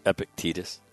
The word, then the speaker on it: Epictetus: If you say it as, if you think of it as two words, Epictetus, it works. When I try to think of it as one, I don't say it right.